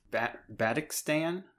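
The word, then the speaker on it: Badhikistan: We've got, and I keep on fluffing the pronunciation on this, Bad Hickstan. One of you can try and pronounce it better than me. Jay had a more valiant attempt, I think, earlier on. – Badikstan, I think. – Badikstan. That sounds good.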